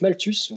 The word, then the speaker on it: Malthus: ou plutôt Thomas Malthus, avec un accent euh, euh, approximatif. Euh, non, en fait, c'était donc Malthus, je vais prononcer à la française.